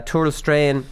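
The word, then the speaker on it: Tourlestrane: Tourlestrain, I'm pronouncing that right. I am.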